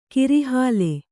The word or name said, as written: ಕೋರೆಹಲ್ಲು